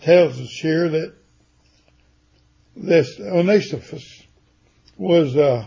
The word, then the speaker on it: Onesiphorus: it's Onesiphorus. – Onesiphorus.